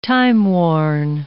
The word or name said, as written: timeworn